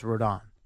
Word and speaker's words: Rodon: Rodon. – Rodon? – Yes.